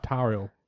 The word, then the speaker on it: Tauriel: I have said that right, Tauriel. – Yes. Yeah, I believe so, Tauriel.